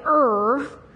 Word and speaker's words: Ur: But Abraham, he was called by God to leave his father's household, to leave his homeland of Ur. I said that right, Ur.